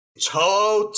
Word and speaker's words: To'o: It's To'o. So like toe and then To'o.